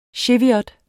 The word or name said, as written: cheviot